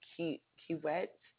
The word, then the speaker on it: Kiewit: And you gave me the name of the company, which was Kiewit. Am I saying that right? Yeah, K-I-E-W-I-T, Infrastructure Co.